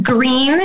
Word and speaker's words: Gruene: But music here, there's Green, Historic Green, and it's spelled G-R-U-E-N-E. It's, everything's German spelling here.